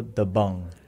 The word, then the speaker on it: Dabang: And the pronunciation for that, it's actually Radio Dabang.